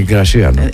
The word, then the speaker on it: Γρασία: Ναι, είπε η αυτή η κυρία Γρατσία. – Η κυρία όχι, συγγνώμη, η Γρασία.